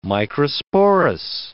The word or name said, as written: microsporous